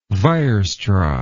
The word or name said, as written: weierstrass